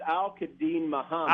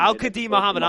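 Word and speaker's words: Al-Quadin Muhammad: how do you pronounce that name there? – Well, did I butcher it? – His name is it's Al-Kadin Muhammad. – Al-Kadin Muhammad.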